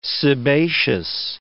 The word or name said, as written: sebaceous